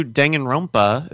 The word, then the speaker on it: Danganronpa: Uh, the new Danganronpa is supposed to come out in the U.S.